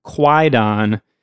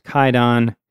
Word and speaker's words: Kwaidan: It's actually spelled K-W-A-I-D-A-N. So it might look like it's pronounced Quaidon, but apparently that spelling is based on a pre-modern Japanese pronunciation. So in modern Japanese, the book is pronounced Kaidon.